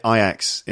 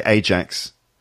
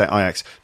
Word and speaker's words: Ajax: By the way, listeners, we do say Ajax in the UK. In they probably have different pronunciations of that name. I think in France, I think they say Ajax. – Ajax. – I'm not sure. But anyway, we say Ajax.